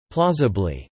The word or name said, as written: plausibly